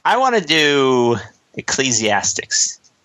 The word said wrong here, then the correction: Ecclesiastes: Ecclesiastes? – In order to do it, you have to pronounce it correctly first. – Okay. Ecclesiastes. – Well done.